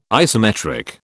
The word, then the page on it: isometric: British English